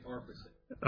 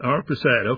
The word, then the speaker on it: Arpachshad: His son, how do you say it, Pat? Arpachshad. Well, Arpachshad. How many? – I would say Arpachshad. – Arpachshad, okay. He lived 438. Sheila lived 433. Eber lived 464. Peleg, P-E-L-E-G, lived 239.